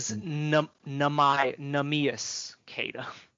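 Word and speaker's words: Neemias Queta: It is Nemias Keita. Apparently, that's how it's said. At least that's what they said on the broadcast.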